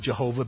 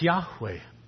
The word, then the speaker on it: Yahweh: We can legitimately pronounce it Jehovah because of the influence of the German language on American. However, if you want to be faithful to the original, his name is Yahweh.